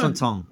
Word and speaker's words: Constantin: By the way, I love the French pronunciation of my name. It's so good. – What, you prefer? – Constantin.